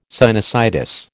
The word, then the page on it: sinusitis: American English